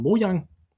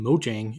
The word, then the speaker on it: Mojang: Og han lavede så et firma, som der hedder Mojang, mm øh, som jeg altid udtalte Mojang i gamle dage, men jeg tror, det hedder Mojang, fordi jeg er svensk, ikke?